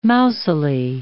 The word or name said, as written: mousily